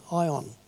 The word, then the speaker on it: aion: It's a word that's spelled A-I-O-N, Ion.